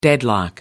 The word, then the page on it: deadlock: American English